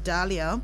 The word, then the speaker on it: Dahlia: Mine is spelled D-A. Delia. You're Delia. I'm Dahlia.